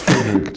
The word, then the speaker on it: Frederic: It's Frederick, dog. – It's Frederick. – Frederick. How do you spell it? – F-R-E-D-E-R-I-C.